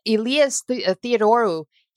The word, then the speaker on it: Elias Theodorou: And, you know, Elias Theodorou, that's how he pronounces it.